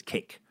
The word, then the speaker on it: KAKE: And K-A-K-E News is simply pronounced as cake.